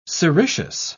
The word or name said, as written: sericeous